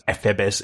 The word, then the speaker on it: ephebes: Ephebes is the proper Greek pronunciation.